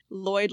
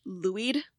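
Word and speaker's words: Lwyd: Lloyd, like the last name, because I watched a video in Wales and they all pronounced it Lloyd, even though I went to a YouTube pronunciation thing and it pronounced it Louid.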